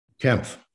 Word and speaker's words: Kempf: Kempf. – Kempf. And that's spelled K-E-M-P-H. Peter Kempf. – P-F. – P-F, excuse me. K-E-M-P-F.